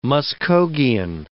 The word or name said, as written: Muskhogean